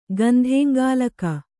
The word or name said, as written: ಗಂಧೇಂಗಾಲಕ